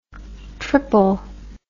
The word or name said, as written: tripple